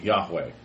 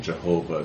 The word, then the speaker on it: Yahweh: And so the correct pronunciation is almost certainly Yahweh, not Jehovah, but that older English understanding Jehovah is still sometimes out there, Jehovah's Witnesses and so on and so forth.